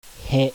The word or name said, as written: HE